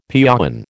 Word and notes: piäon: American English

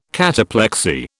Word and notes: cataplexy: British English

